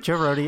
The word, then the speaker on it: Joe Rohde: I know, if you know who, like, Joe Rohde is, I don't know if I said his name right, I probably butchered it, but, Joe Rohde, yep.